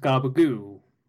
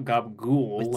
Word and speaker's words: gabagool: It's like how it's pronounced gabagoo, not gabagool. That's how you type it out. That's how you write it. That's not how you say it.